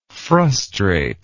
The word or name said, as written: frustrative